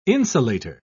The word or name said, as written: insulator